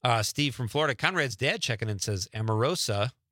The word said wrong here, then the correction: Omarosa: I think I'm pronouncing that right. Omarosa, because she reminded me of the worst boss I experienced in the 90s.